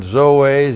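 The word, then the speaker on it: zoe (ζωῇ): Zoe autos. The preposition in, and then zoe, z, long o, long e, n, zoe, life.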